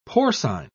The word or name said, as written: porcine